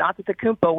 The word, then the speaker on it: Antetokounmpo: And then you can pronounce it the way I just did, Antetokounmpo, where there's more T's and P's. That's more of the Greek version.